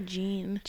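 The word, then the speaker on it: Jeanne: It's J-E-A-N-N-E. – I would say Jean.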